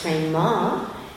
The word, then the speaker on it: prema: You notice it has a long A. It's not prema, it's prema. And that's because it is feminine.